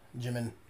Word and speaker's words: Jymn: I think that's Jim. That's just a weird way of spelling it. – J-Y-M-N. Jimin. – Jim. Jim. – The N is silent.